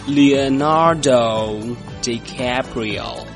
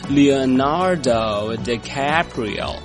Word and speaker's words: Leonardo DiCaprio: 他的名字的原名呢是念作，来和我一起念，念作 Leonardo DiCaprio。再来一遍， Leonardo DiCaprio。